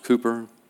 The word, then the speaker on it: Cowper: It's spelled Cowper, but it was pronounced Cooper.